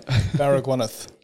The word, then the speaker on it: Baragwanath: Baragwanath.